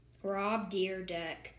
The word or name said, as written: Rob Dyrdek